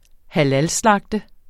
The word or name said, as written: halalslagte